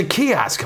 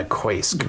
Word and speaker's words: kiosk: I was, they'd be like, oh, that's a kiosk. I'm like, how do they know it's not a koisk? But I didn't want to ask. Do you know how I found out I was saying it wrong?